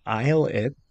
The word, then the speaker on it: ILLIT: But starting off on Monday, we have a group called Islit, I-L-L-I-T, with a mini album. – That's how you pronounce it. – Yeah, Islit. – Been reading it wrong in my head this whole time. – I'm like, Illit.